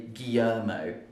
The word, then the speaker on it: Guillermo: So I think in Spanish it would be something like Guillermo. But in English we would say Guillermo, because we don't pronounce it properly.